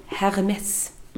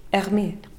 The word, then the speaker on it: Hermès: Men man ska inte säga med S på slutet, men jag kan inte uttala Hermès på något annat sätt. Hermès.